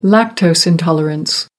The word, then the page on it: lactose intolerance: American English